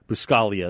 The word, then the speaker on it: Buscaglia: Buscaglia is how he said it, but it's spelled Buscaglia, B-U-S-C-A-G-L-I-A,